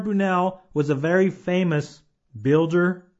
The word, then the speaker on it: Brunel: His first name is Isambard, and you spell that I-S-A-M-B-A-R-D, Isambard. And his last name is Brunel, and that is spelled B-R-U-N-E-L, Brunel.